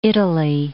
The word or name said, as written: Italy